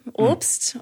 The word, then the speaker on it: Obst: Obst, O-B-S-T. Like I always introduce myself in America because no one can pronounce my last name. It's German.